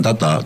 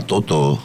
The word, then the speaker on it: Toto: Bacanya bukan Tata, Toto.